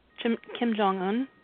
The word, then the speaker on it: Kim Jong-un: Kim Jong-un.